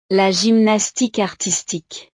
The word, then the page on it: la gymnastique artistique: French